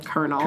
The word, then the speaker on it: colonel: Fuck, I always say colonial instead of colonel. – Colonel, yeah. – Lieutenant colonel. – Well, that's stupid. Wherever that's from, that's fucking whoever, stupid. – It's spelled like colonial.